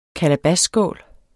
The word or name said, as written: kalabasskål